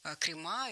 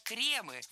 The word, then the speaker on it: кремы: Крема, неверно, правильно, кремы. В этом существительном мы тоже сохраняем ударение на корне во всех формах. Кремы, кремов, кремами, кремах.